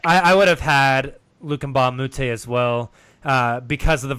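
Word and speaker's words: Mbah a Moute: Luke Richard Mbamute.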